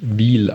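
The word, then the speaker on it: Wil: Ich habe mir gerade die Folge vom letzten Exil-Hertaner angehört und da sprecht ihr auch über den Schweizer Zweitligisten FC Wiel und da wird tatsächlich Wiel ausgesprochen, nicht Will oder so wie ihr das da genannt habt.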